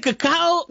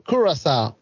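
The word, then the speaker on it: Curaçao: Yo, so is it Cacao or is it Curacao? – It's Curacao.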